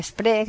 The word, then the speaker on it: Spree: Pues hicimos otro recorrido por el Spree, que se escribe con S líquida, S-P-R-E-E, que esa doble E no es Spree, porque esto no es inglés, es alemán.